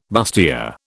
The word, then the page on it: bustier: British English